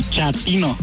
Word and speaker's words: chatino: Chapino. – ¿Chapino? – No, chatino. – Es como chato, ¿verdad? Chatino. – Sí, la primera como chato, chatino.